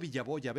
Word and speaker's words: Villaboya: Nabor Villaboya, B chica, B grande y doble doble L.